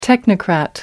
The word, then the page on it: technocrat: American English